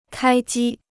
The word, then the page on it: 开机: kāi jī